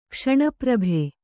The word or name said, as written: ಕ್ಷಣಪ್ರಭೆ